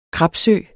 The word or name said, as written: krapsø